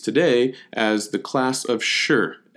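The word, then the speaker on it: Shi (士): S H I is how you write this down in modern day romanization, and it's pronounced like the English word sure. You know, like, hey, yeah, sure, I'll go. Um, the sure.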